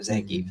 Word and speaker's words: Zangief: When Zangief said his own name. – Mm-hmm. – That it was like established that is the pronunciation of Zangief.